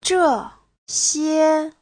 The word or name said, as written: zhe4xie1